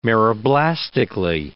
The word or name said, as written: meroblastically